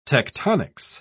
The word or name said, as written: tectonics